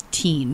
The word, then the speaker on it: Thein: And like it was T-H-E-I-N, but it was Teen. That's how she pronounced it.